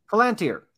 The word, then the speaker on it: Palantir: I mean, even the, I always say it wrong. So I'm going to call it a plantain, the Palantir.